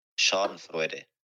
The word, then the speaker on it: Schadenfreude: So, uh, this is pronounced Schadenfreude.